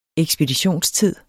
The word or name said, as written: ekspeditionstid